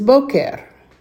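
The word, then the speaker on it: boker (בוקר): The second way with a cholam, which again has the O sound, is boker. And that's bet cholam kuf segol resh. Boker. Notice that tov and boker both sound exactly the same as far as the long O sound.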